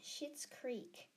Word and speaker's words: Schitt's Creek: Schitt's Creek. I did not do swear then. It's spelled S-C-H-I-T-T-S. Schitt's Creek.